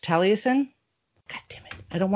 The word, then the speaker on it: Taliesin: It's Taliesin.